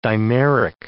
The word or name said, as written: dimeric